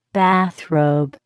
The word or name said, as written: bathrobe